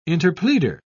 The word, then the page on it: interpleader: British English